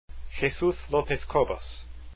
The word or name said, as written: lopez cobos